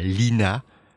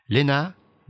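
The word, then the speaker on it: Lenna: Elle insiste pour être créditée sous le prénom de Lena avec deux N et non Lena avec un seul N, pour que la prononciation reste bien Lena et pas Lina, comme elle l'a sans doute déjà entendu aux États-Unis.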